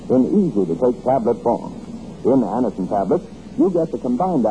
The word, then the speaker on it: Anacin: Remember the name, Anacin. It's spelled A-N-A-C-I-N. Anacin.